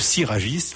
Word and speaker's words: Sirajis: Le groupe Siragis, alors S-I-R-A-J-I-S. – Comme ça se prononce.